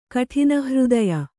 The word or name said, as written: ಕಠಿನಹೃದಯೆ